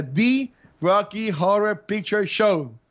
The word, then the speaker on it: The Rocky Horror Picture Show: pero vamos a estar hablando sobre The Rocky Horror Picture Show.